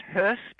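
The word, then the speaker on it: Höss: The other Hess, it's pronounced Hess because it's H-O with two dots on the O. That's an H-O umlaut, S-S.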